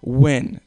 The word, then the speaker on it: Nguyen: But when people mispronounce my last name, it's Nguyen. Win. Simple, right? Because Vietnamese is a monosyllabic language. One syllable per word.